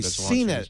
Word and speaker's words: CNET: I'm sorry, CNET.